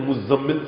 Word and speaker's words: مزمل: قرآن میں فرما رہا ہے پروردگار عالم، ان سنلقی علیک قولا ثقیلا، سورہ مزمل، مزمل کہتے ہیں لیکن مزمل صحیح تلفظ ہے.